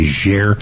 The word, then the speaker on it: Niger: In fact, where I was most recently in Niger, it's a French colony, or was, and so they pronounce it Niger.